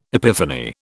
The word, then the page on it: epiphany: British English